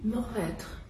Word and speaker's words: Mourvèdre: It's a Mourvèdre. I hope I'm saying it correctly. – Mourvèdre.